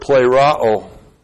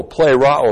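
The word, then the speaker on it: pleroo (πληρόω): And that word, to be filled with the Spirit, is the Greek word pleroo. P-L-E-R-O, long O, pleroo.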